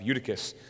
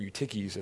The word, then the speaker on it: Eutychus: And lastly, there is the account of Eutychus, or Eutyches as some pronounce it,